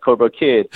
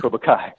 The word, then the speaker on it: Cobra Kai: They sometimes get the name wrong. They're like, Cobra Kid. I'm like, no, man, it's Cobra Kai.